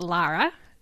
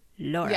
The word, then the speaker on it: Laura: Not Lara. Laura. Yeah. As my, I had an ex coworker who I mistakenly pronounced her name Lara, and she's like, it's not Lara, it's Laura.